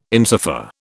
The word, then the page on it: insofar: British English